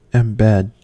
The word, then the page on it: embed: American English